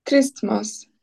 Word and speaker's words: Christmas: So it's not Christmas, so it's not the ah. Okay, and the T here, it's not really pronounced.